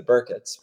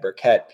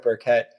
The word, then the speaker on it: Burkett: Most of my family pronounces us as the Burkitts, but I find that when I introduce myself as Dan Burkett, people hear that sharp syllable. So I go with Burkett.